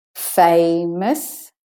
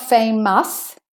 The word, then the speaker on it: famous: Famous. So it's not famous, it's famous. The OUS is de-stressed. It's the schwa vowel.